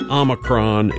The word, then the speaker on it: Omicron: Almost anything works, but Omicron is exactly right. It's fine. The Oxford Dictionary includes Omicron, and in classical Greek it was pronounced with a second syllable, like Omicron, like me, you know.